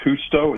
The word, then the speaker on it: Justo: And just a quick correction, if you don't mind, my last name is pronounced Justo. It's Spanish. – Ah, so the J, okay. The J is pronounced like an H, like Jose.